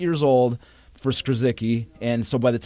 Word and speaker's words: Skrzycki: I'm going to pronounce this, the Polish name, Skrzycki. So it's spelled S-K-R-Z-Y-C-K-I, Skrzycki. I hope I'm pronouncing that right.